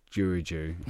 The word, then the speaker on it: Giurgiu: Giurgiu.